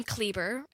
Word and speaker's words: Kleber: My last name, Kleber, it rhymes with Justin Bieber.